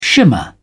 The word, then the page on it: shimmer: British English